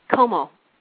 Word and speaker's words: Como: Como.